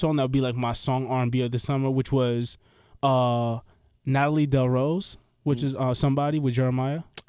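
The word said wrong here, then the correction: La Rose: Oh, I said her name wrong. Natalie. – Natalie LaRose. Yeah.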